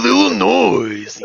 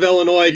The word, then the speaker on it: Illinois: West Side of Springfield, Illinois. Yeah. – Did I catch an S on the end of Illinois? – Yes. That's how Macho Man Randy Savage says Illinois, because that's how it's spelled.